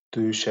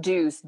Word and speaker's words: Dooce: And just for those who don't know, is it pronounced as Douce or? – It's pronounced Deuce, D-O-O-C-E, Deuce.